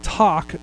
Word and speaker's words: Toq: Talk, and of course they couldn't spell it like you would pronounce it. It's spelled T-O-Q, but the Talk smartwatch came out this last week, and I was underwhelmed.